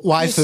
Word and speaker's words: waifu: Waifu is how you pronounce it. – Waifu. – You're so stupid. You're so stupid. – Waifu.